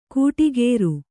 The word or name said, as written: ಕೂಟಿಗೇರು